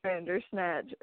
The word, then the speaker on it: Bandersnatch: The movie, what's crazy is I really wanted to watch Bandersnatch. Or, is that how you say it? – Yeah.